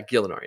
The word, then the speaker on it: Gielinor: Or did I say that right? Yeah, Gielinor. Yeah. Yeah, and it's a G-I-E, so Gielinor, maybe?